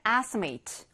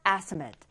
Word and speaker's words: estimate: 자, 21번째, estimate or estimate. 여기 A 사운드가 8라고 발음이 되면 동사예요. 그리고 estimate이라고 해서 사운드가 나면 명사로 보통 쓰입니다.